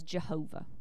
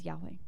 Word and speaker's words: Yahweh: We have heard this, and we pronounce it as Jehovah. And there are reasons why we won't discuss that. It is more traditionally correct to as Yahweh. YHWH. More correct as Yahweh.